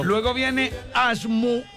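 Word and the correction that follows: Ashurmatov: Epa, ahí me equivoqué ya arrancando. Es Ashumatov. Ashumatov. Luego viene Abdulayev.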